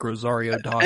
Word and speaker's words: Rosario Dawson: Soon as Ahsoka was cast as Rosario Dawson, is that how you say her name? – Yeah.